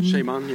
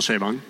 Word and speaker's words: Chabon: But I also really loved The Mysteries of Pittsburgh by Michael Chabon. – Chabon, yeah. – I don't know. Does anyone really know how to say his surname? – It's Michael Chabon.